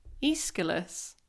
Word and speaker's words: Aeschylus: Well, I'm probably going to butcher his name, because so my English lecturer at university swore down that this was how you pronounced Aeschylus's name. However, I have since looked it up on the internet, and we all know how reliable the internet is, but the internet tells me that it's Aeschylus, not Aeschylus.